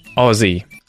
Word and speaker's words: Aussie: It's Aussie, Aussie, right? The vowel sound in words like on or off, ah, ah, ah, Aussie.